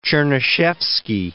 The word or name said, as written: Chernyshevsky